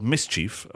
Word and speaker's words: MSCHF: So there's a company and what they've done, they are called Mischief, of course, but it is spelled M-S-C-H-F.